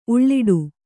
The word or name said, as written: ಉಲ್ಟಾ